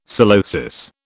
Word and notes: psilosis: British English

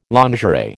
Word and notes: lingerie: American English

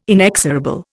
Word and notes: inexorable: Indian

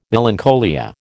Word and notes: melancholia: American English

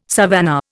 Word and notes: savannah: Indian

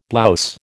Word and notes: blouse: American English

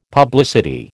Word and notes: publicity: American English

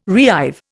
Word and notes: reive: Indian

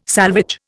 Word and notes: salvage: Indian

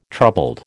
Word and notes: troubled: American English

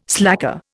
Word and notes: slacker: Indian

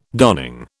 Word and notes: darning: British English

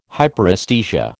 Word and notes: Hyperesthesia: American English

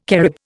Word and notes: Carib: Indian

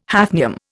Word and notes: hafnium: Indian

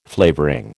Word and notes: flavouring: American English

